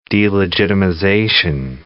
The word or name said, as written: delegitimization